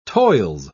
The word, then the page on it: toils: British English